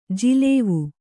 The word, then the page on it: ಜಲಾವು: jalavu